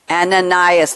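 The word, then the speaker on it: Anaïs: He has quoted, I'm never sure how to pronounce her first name. Anais Nin, am I saying it right, or Anais Nin? – Anais, there you go.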